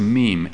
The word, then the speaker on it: meme: Now, in this book, Dawkins introduced the term, and I think it's pronounced meme, M-E-M-E.